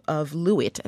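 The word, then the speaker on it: Luit: You provide the example of Lewitt, am I saying that correctly? – Yeah, yeah.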